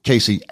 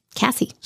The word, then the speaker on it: Kassi: And last week we wondered if it was Cassie or Casey Ashton. K-A-S-S-I is the spelling. Her people listened to the podcast at Universal Music, EMI, and reached out. Rhymes with sassy. – Cassie. – So it's Cassie Ashton.